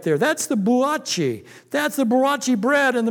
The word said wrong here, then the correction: brioche: Did I say buachi right, Donna? Huh? Huh? Brioche? It should be brioche.